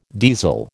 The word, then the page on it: diesel: American English